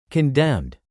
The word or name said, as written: condemned